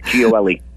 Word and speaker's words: Gioeli: But it's just simply pronounced Gioeli. If you say four letters, G-O-L-E, you say those four letters fast, G-O-L-E, you got it, Gioeli.